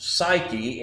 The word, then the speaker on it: psyche: The Greek word translated soul in the New Testament is psuche. And as the word was anglicized, it eventually became pronounced psyche.